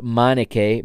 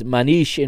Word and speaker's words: Manniche: I believe the way it's pr- the English commentators pronounces is is Michael uh, Manique, but it is Manish in Portuguese or Manique uh, to some.